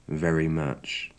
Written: very much

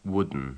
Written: wooden

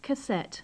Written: cassette

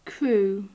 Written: crew

